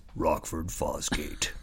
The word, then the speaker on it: Rockford Fosgate: You have to say it like this. Rockford Fosgate.